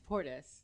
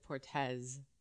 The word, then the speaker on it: Portis: Well, it's Portis, like tortoise, right? – Yeah. – But my sister wants to think of herself as being very Latin, so she pronounces it Portes, the Z.